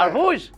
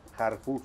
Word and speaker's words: Harfuch: Al García Harfuch. – Salud. – No, así se llama. Harfuch. – Harfuch. Pues es más suavecito, es Harfuch. – Ay. – Es libanés.